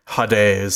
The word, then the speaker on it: Hades: For me, it's Doom Eternal, but I don't know what Hades is. Is that how you pronounce it? – God bless America. – Hades.